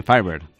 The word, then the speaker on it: Firebird: El Firebird. – Sí, Firebird. – ¿Lo digo bien? ¿Hablo bien mi inglés? – Sí, muy bien, Firebird. Muy bien.